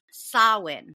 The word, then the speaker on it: Samhain: With that being said, it's Samhain. So the Gaelic word pronunciation Samhain was the name of the Celtic festival at the end of the summer to welcome the harvest.